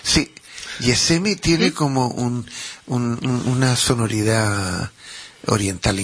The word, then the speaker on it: Yessemi: Pero claro, corríamos el riesgo de gente que no fuera de aquí, que hablara el catalán... pronunciaran Gesemi, era Gesemi, no, es como, uff, no me gusta, ¿no? Vamos a ponerle, Yesemi. Vamos a cambiar esa Yesemi. G por una Y, que suena más dulce.